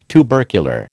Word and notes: tubercular: American English

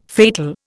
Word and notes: fatal: Indian

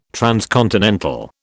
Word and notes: transcontinental: British English

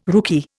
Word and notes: rookie: Indian

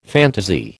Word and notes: phantasy: American English

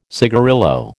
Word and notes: cigarillo: American English

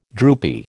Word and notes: droopy: American English

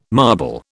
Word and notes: marble: British English

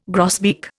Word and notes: grosbeak: Indian

